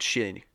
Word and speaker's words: Xin: I believe the name is pronounced Xin. – Oh, I thought you were saying she. You're like, she went to go find another killer. And I was like, oh, okay, it's a lady.